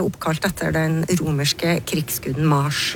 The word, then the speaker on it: Mars: I latin så ble navnet til krigsguden Mars uttalt, nettopp med lang A. Mars.